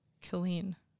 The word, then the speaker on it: Killeen: Killeen. – Killeen? – Two L's, two E's. Killeen. – Killeen.